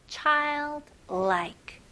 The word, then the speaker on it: childlike: 여기서 L을 너무 강하게 하면 발음하기 되게 어렵게 됩니다. 그래서 child보다는 child, child. 그 다음에 두 번째는 like라는 단어도 있잖아요. like. 자, 연결하면서 childlike, childlike.